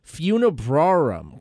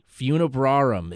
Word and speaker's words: Funebrarum: And let me make sure I'm saying it right. Funebrarum, correct? – Yep, hit the nail on the head there, man. – Funebrarum.